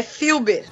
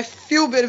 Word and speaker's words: Fulber: Normalmente eu tenho que dizer que é Fulber mesmo, que senão a pessoa escreve com i, né? Mas é é Filber mesmo que se é pronuncia. É alemão.